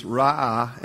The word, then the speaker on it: ra'ah: So, the Hebrew word for shepherd is ra'ah, and it's spelled R-A, a little apostrophe A, and it really means this whole idea to feed, to guide, to shield.